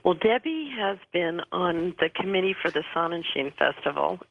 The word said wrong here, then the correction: Sun and Shine: Did I pronounce that right, Debbie? – Sun and Shine.